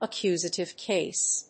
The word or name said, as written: accusative case